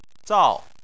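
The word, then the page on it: zao: tone 4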